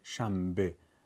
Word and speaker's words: شنبه: مثلا شنبه، نون تبدیل به میم میشه شنبه، برای اینکه بتونیم آسان‌تر کلمات رو تلفظ کنیم.